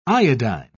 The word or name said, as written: iodine